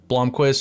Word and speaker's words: Blomqvist: They're going to play the patient game with Blomqvist. Is that how you say it? Mm-hmm. Blomqvist.